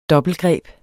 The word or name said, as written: dobbeltgreb